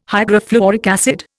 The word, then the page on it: hydrofluoric acid: Indian